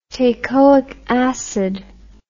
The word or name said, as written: teichoic acid